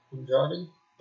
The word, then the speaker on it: Andrade: Andrade.